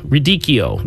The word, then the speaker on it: radicchio: It's called radicchio. And that's very R-A-D-I- C-C H-I-O, radicchio. That's what it's pronounced, radicchio.